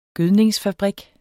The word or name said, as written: gødningsfabrik